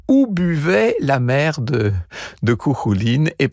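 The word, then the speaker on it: Cú Chulainn: C'est Julia Lebrun qui est allée fouiller tout ça, aller puiser des éléments de la vie de Kouhouline dans, encore une fois, ça s'écrit Kouchoulaïn, hein, dans les 76 récits en question.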